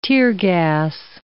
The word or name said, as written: teargas